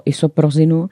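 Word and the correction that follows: Isoprinosin: nebo isoprosin, jaká je správná výslovnost? – Izoprinozin. – Ano. Dobře, už to budu říkat správně.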